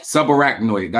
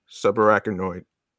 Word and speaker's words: subarachnoid: Subarachnoid. That's how you say it. You heard? – Yeah. – Listen. – Subarachnoid.